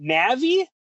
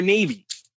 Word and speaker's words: Navi: Now, let me ask you, do you pronounce it Navi? Or Navy? – Navi.